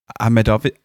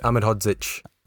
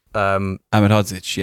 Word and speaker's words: Ahmedhodžić: Ahmedzovic? That's not how you pronounce it. – Ahmedhodžić. – Ahmedhodžić, yes.